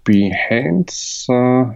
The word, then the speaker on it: Behance: Auf Behance, ich glaube, ich habe es richtig ausgesprochen, ja.